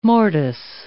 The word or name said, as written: mortice